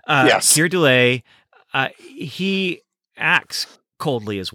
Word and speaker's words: Keir Dullea: It's very calculated, and and Kira Dula, we just looked up how to how to say his name, Keir Dullea. – Dullea. – Keir Dullea.